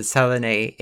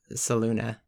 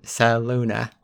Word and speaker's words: Saluna: No, because I remember I talked about this with Sarah because we had a whole thing, because we always pronounce it Salune in our campaign, but we learned in this it's Saluna. – But it's in our mind space. – Okay, because it's like Luna, like the Saluna.